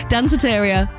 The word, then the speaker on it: Danceteria: So this next track is called Danceteria, a bit like a cafeteria, but you're meant to be dancing, everybody. And I think you will be when you hear this, Madonna, Danceteria. Did I say that right? – I think you said it great.